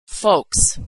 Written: folks

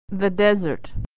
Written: the desert

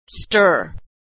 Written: stir

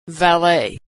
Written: valet

